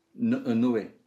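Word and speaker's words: Noé: How is it pronounced? – Noe.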